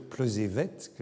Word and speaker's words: Plozévet: Elle est née à Plozévé, et elle me corrige même, elle me dit qu'il faut dire Plozévette, que le T final se prononce.